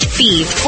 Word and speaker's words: Fives: F-I-V-E-S, like the number, but pronounced Fieve.